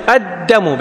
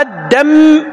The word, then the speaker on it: الدم: الدم نفسه، الدم، الدم بتشديد الدال وتخفيف الميم، أما الدم هذا غلط، الدم هذا غلط ترى.